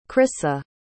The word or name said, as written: crissa